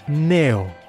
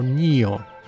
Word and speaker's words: Neo: Y estoy hablando, en primer caso, de el Surface Neo, o NIO, pronunciado más en inglés.